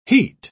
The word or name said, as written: heat